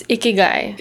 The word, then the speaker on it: Ikigai: It's Ikigai. – Ikigai.